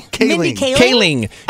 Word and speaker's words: Kaling: Kaling.